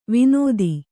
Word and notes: ವಿನೋದಿ: vinōdi

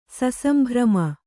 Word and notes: ಸಸಂಭ್ರಮ: Kannada; sasambhrama

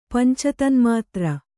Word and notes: ಪಂಚತನ್ಮಾತ್ರ: panca tanmātra

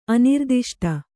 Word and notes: ಅನಿರ್ದಿಷ್ಟ: anirdiṣṭa

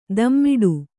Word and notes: ದಮ್ಮಿಡು: dammiḍu

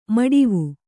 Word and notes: ಮೊಡವೆ: moḍave

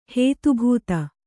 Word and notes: ಹೇತುಭೂತ: Kannada

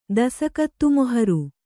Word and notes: ದಸಕತ್ತುಮೊಹರು: Kannada

